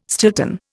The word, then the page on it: Stilton: Indian